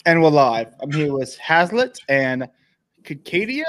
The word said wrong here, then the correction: Chayada: Is that how you say it? – Chayada. – Chayada. I was way off.